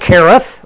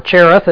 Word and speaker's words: Cherith: For he went and he stayed by the brook Cherith, or Cherith as we often pronounce it, which flows into the Jordan.